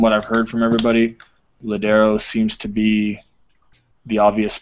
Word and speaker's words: Lodeiro: To be honest with you, I think that Lodeiro, or is that how you say his name? – Lodeiro.